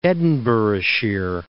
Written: Edinburghshire

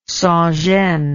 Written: sans gêne

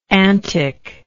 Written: anticcally

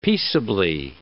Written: peaceably

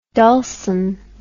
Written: dulcin

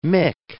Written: mick

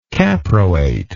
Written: caproate

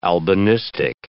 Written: albinistic

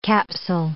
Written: capsule

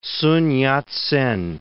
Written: Sun Yat-sen

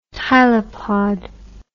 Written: tylopod

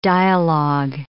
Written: dialog

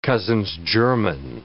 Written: cousins-german

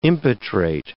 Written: impetrate

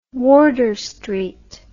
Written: wardour street

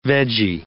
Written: veggie

